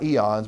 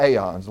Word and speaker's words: aeons: Not eons, but aeons. At least that's how I pronounce it.